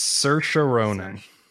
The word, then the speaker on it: Saoirse Ronan: I can tell you, it's Saoirse Ronan.